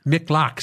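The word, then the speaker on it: Miqlat: Did I pronounce it? M- Miklak. Miklak. See, there she goes.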